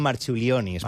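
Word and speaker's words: Marčiulionis: En tot cas, l'escena on Nelson i Marciulionis, no sé si ho he acabat de dir bé. – Jo crec que li diuen Marciulionis, però... Marciulionis. Perquè que aquesta C es pronuncia com una TX.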